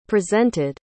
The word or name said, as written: presented